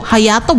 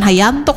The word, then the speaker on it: Hayato: Hayato bukan Hayanto, kok Hayanto sih dibacanya. Hayato.